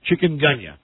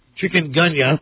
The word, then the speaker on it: Chikungunya: Now, how do you pronounce C H I K chick? UN, Chikungunya. Thank you. – Mm. – Chikungunya.